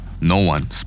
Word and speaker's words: Nowon: No One, it's spelled N-O-W-O-N.